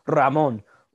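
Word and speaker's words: Ramón: No, please roll the R. You speak Spanish, roll your R's. – Ramon. – Thank you. – Pretty much Ramon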